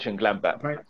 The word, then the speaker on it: Mönchengladbach: And then Grujic, they're saying as well that Borussia Mönchengladbach, I think that's how you pronounce it. – Mönchengladbach.